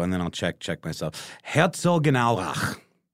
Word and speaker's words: Herzogenaurach: It's Herzogenaurach.